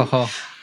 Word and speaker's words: Forró: The only thing that I sort of have been listening to more of is it called Forró? Is that am I saying it right? – Forró. – Forró. Yeah.